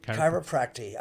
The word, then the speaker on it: chiropractic: But chiropractor, how do you say it? – Chiropractic. Chiropractic. That's how you say it.